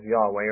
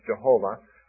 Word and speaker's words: Yahweh: It's actually only four letters, J-H-W-H. Which can be pronounced, if you add vowels, Yahweh, or it can be pronounced Jehovah, and different Bible translations prefer one or the other.